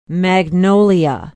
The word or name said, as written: magnolia